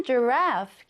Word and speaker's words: giraffe: turtle, T-U-R, 그리고 T-L-E. turtle, 거북이가 됩니다. 다음 단어는 giraffe, 기린인데요. 두 번째 음절에 강세를 넣어줍니다. giraffe 한번 써볼까요? G-I, 그리고 R-A-F-F-E 하면 giraffe, 기린이고요.